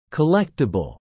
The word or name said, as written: collectible